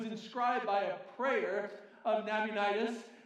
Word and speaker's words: Nabonidus: And the reality is that there was a guy named Nabonidus who was, no, I'm sorry, I, I pronounced that wrong, uh, Nabonidus, who was the last king of the Babylonian Empire, and everybody knows that.